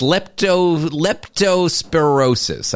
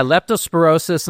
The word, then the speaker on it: leptospirosis: What, it's leptospirosis. I said that right, yes. – Yeah, leptospirosis, and I talked about this before the break because it was a problem in Jamaica.